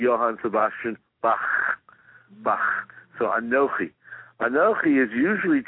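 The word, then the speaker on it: Anochi (אנכי): I heard you pronounce it earlier, much like a like an Italian delicacy. Anochi. But it's Anochi.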